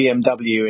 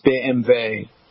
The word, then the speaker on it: BMW: They call it a BMW, and it's actually a BMW, a BMW, because in German, W is pronounced V. So it's BMW.